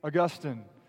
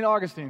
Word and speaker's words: Augustine: The prolific theologian, St. Augustine, or St. Augustine, however you prefer to pronounce his name.